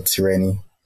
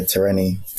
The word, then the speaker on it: Tireni: So Tireni would be how you should pronounce it, but I go Tireni, T.